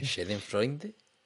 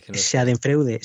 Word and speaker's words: Schadenfreude: Schadenfreude. ¿De amigo? – Schadenfreude.